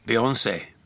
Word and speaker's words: Beyoncé: Beyoncé.